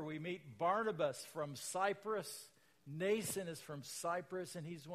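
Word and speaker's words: Mnason: Some of the disciples from Caesarea accompanied us and brought us to the home of Nason, don't pronounce the M, where we were to stay.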